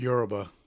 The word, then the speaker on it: Yoruba: Yeah, Yoruba. – Yoruba. – Yeah. – Thank you. Oh, thank you. Somebody's here to check me. – Put the wrong emphasis on the wrong syllable.